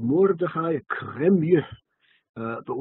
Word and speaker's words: Cremieux: His name was not really Karmi, even though that's the way it's usually pronounced in the yeshiva world. He was from France, uh, 18th uh, century France, and uh, he was the Rav of the town of Cremieux.